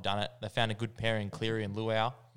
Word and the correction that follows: Luai: Mm. – Is that right? – Luai. – Yeah.